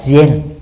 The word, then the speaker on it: Zen: Nhật Bản gọi chữ Thiền trong Hán Việt đó, theo cách phát âm của họ là Zen, tức là viết cùng một chữ thôi, khác nhau về phát âm.